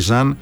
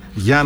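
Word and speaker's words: Jean: Επειδή ξέρεις ότι έκατσα και τάκουσα λίγο πώς θα προφέρουν οι ίδιοι, είναι Μπράνκου Μπάντιο, ο Σενεγαλέζος, και Γιάν, και όχι Ζαν, Γιάν Μοντέρο.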